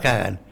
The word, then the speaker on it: Kaghan: creo que era K-A-G-H-A-N, pero se pronunciaba Kagan.